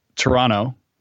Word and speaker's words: Toronto: And by the way, my friends from Toronto, they would tell me it's pronounced Toronto with just an N, no T at the end. Toronto.